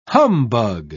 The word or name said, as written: humbug